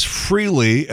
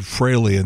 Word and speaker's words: Frehley: 64, Ace Frehley, and I believe that is the correct pronunciation. For years we said Frehley, and that's wrong.